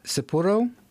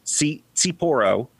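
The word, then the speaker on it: tsipouro: Its roots can actually be dated to a sort of brandy called Sipuro, or Sipuro, T-S-I-P-O-U-R-O. I know the T is silent, but I'm not totally sure how to pronounce it. – Yeah, it sounds like it's supposed to be pronounced like Sapporo.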